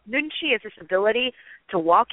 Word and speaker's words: nunchi: Is that pronounced correct? Nunchi? Nunchi? – Yeah. You must be Korean, because your pronunciation is spot on.